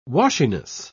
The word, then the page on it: washiness: British English